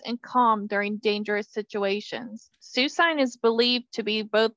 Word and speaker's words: Suicune: It's Suicune.